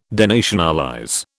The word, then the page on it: denationalize: British English